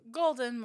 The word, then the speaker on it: Golden: Golden, more like the color.